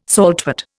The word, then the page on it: saltwort: Indian